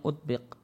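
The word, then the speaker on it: utbiq (أطبق): utbiq. Ini bacanya utbiq.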